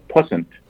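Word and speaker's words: puissant: So the word that they had the other day, and I'm going to make sure I say this right, is pussent.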